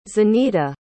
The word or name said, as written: zanita